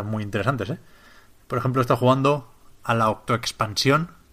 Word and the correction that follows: Octo Expansion: de u, Octo Expansion, porque no lleva acento en castellano tampoco, de Splatoon 2.